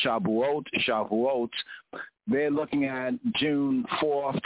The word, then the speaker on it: Shavuot: Shabuot, called by other Yehudi, other Jews, would enunciate it, pronounced as Shavuot.